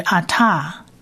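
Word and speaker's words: ata (אתה): 而第三个字呢，它是希伯来文字母的 hey，它原则上是发 h 的音，那放在字尾的时候就当作是 h 的音，不发音，所以是 ata 是代名词你的意思。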